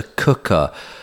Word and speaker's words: Quooker: It's the cooker. Spelt quooker, Q-U-O-O-K-E-R, but pronounced cooker.